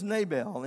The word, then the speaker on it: Nabal: His name was Nabal, N-A, long A, B-A, long A-L.